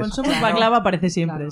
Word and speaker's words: Backlava: Baklava se escribe BACKLAVA. O sea, como back de espalda y lava.